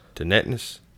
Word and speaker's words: tinnitus: Tinnitus? – I don't know.